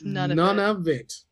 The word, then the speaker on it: Nunavut: I just listened to how to pronounce it. It's none of it.